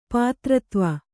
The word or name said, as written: ಪಿತೃತ್ವ